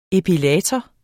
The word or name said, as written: epilator